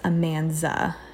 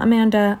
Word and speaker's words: Amanza: And I'm not saying her name wrong. Her name is Amanza. Not Amanda, Amanza, just so you know.